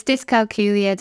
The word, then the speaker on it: dyscalculia: So it's dyscalculia.